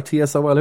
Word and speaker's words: TSOL: China White, Wasted Youth, T-Sol or T-S-O-L, how do you pronounce T-Sol? – T-S-O-L.